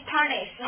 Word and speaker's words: punish: 所以这单词的发音 parnish 哈，怕你死了，是不是？